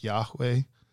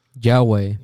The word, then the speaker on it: Yahweh: Uh, they, you can't say it, you can't write it, so they would represent it in four letters in uh, Hebrew, but the approximate pronunciation is something like Yahweh, Yahweh. Yahweh, Yahweh. It's the Anglicization of it. They make it Jehovah, right?